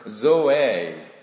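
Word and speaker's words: Zoe (ζωή): The first Greek word you wrote down on your paper, zoe. Z-O-E, but it's pronounced in Zoe in Greek.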